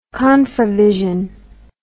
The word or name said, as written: confravision